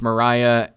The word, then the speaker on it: Maria: It's spelled Maria, and in the musical, it's pronounced Mariah.